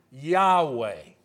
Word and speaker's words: Yahweh: Usually it is pronounced by Jewish people as what? Yahweh. Y-A-H-W-E-H.